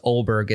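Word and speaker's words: Åberg: Because the announcers were saying Oberg. – Well, it's pronounced Oberg. It's A-B-E-R-G, but I believe there's two little dots.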